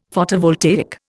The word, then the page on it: Photovoltaic: Indian